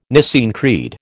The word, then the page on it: Nicene Creed: American English